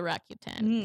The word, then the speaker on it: Accutane: But she does have glowing, radiant skin because of the Accutane. Is that how you say it? – Yeah. I say Accutane. I know that here people say Accutane. But I say Roaccutane.